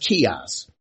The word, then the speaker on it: Chios: It's pronounced Chios.